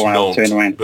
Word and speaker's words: Nantes: But yeah, there's plenty around us, but it's, I'm talking about, I think it's pronounced Nantes, but okay.